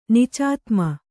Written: ನಿಚಾತ್ಮ

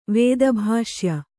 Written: ವೇದಭಾಷ್ಯ

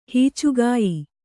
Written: ಹೀಚುಗಾಯಿ

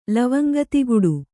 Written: ಲವಂಗತಿಗುಡು

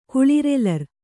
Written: ಕುಳಿರೆಲರ್

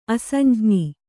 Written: ಅಸಂಜ್ಞಿ

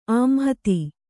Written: ಆಂಹತಿ

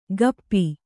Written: ಕಪ್ಪಿ

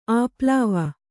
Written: ಆಪ್ಲಾವ

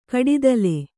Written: ಕಡಿತಲೆ

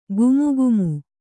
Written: ಘಮಘಮ

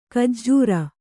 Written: ಖಜ್ಜೂರಿ

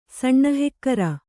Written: ಸಣ್ಣಹೆಕ್ಕರ

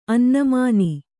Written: ಅನ್ನುಮಾನ

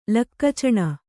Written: ಲಕ್ಕಚಣ

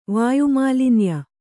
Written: ವಾಯುಮಾಲಿನ್ಯ

